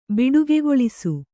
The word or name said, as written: ಬಿಡುಗೆಗೊಳಿಸು